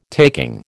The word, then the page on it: taking: American English